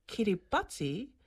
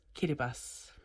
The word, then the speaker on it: Kiribati: Okay, did you know the word Kiribati is spelt with a T-I at the end of the word? So it looks like Kiribati, but when T-I are together in the Kiribati language, it's pronounced like an S. So it's not Kiribati, it's Kiribati.